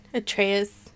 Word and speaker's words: Atreus: Is it, am I saying that right? Artus? Now I'm realizing I don't know the son's name, which is terrible because I beat that game this year. – I think Atreus sounds right.